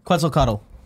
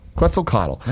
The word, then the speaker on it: Quetzalcoatl: Quetzalcoatl. – Quetzalcoatl.